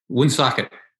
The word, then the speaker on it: Woonsocket: And you said it about right. Woonsocket. – Oh, is that how it's pronounced? – Right.